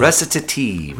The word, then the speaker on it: recitative: Recitative. – Recitative. It sounds so international. – It's an Italian word, so we're all saying it wrong.